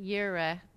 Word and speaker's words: Jireh: Jehovah, you're supposed to pronounce it Yireh, J-I-R-E-H, and it means God is my provider.